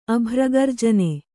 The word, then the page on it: ಅಭ್ರಗರ್ಜನೆ: abhragarjane